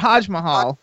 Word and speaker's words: HodgMahal: Is that how you pronounce it, Hodge Mahal? – It's like Taj Mahal, but Hodge Mahal.